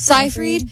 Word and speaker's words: Seyfried: It was about Amanda Seyfried. Is that how you say it? Seyfried. – Seyfried.